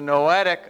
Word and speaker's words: noetic: The technical term that you've heard before is the noetic effects of sin, not spelled like Noah, but N-O-E-T-I-C.